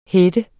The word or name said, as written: header